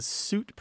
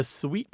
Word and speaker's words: suite: There's an interesting fact that the suit pronunciation used to be one of the old pronunciations of S-U-I-T-E. So you did have two pronunciations of that word, but they both coexisted and they fixed themselves in different parts of the English-speaking world. The other thing is the suite pronunciation is kind of marked as fancy. It's Frenchified.